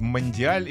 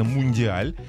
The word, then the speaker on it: мундиаль: Во-первых, мне стало интересно, как правильно говорить, мандиаль или мундиаль. Оказывается, правильно, мундиаль. Угу. Вот, что лично мне режет ухо.